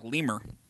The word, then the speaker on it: LMIRL: What does L-M-I-R-L mean? – L-M-I-R-L? – Yep. – Well, you say it like lemur.